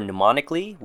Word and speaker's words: mnemonically: M for mnemonically, which means relating to, assisting, or intended to assist the memory. The M is silent for pronunciation.